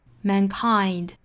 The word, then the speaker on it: mankind: 那这个词我们要注意它的重读音节呢应该是在第二个音节，所以读起来应该是 mankind。